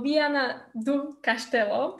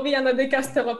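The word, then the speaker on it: Viana do Castelo: Zistili, že sme mali rovnakú cestu, z Viana du Castello, ako ma to Dominik naučil vyslovať. tak, tak. – Predtým to bolo Viana de Castello pre mňa, áno, no teraz.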